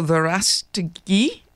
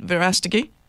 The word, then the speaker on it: Verástegui: And I'm back with the executive producer of the movie Cabrini, Eduardo Verástegui. Did I pronounce that right, Verástegui? – Perfect.